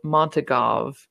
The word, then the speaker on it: Montagov: There's the Russian quadrant, which Roma, I assumed his last name was Montagov. It's spelled like that. That's like the literal pronunciation is what I assumed phonetically. – I think that's what she says in the audiobook.